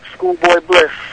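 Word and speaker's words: Skoolboy Bliss: At Schoolboy Bliss, spelled with a K.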